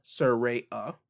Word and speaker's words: Saraya: After this, we will have Saraya. Yes, that's the way to pronounce Paige's actual name, Saraya.